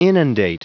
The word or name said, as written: inundate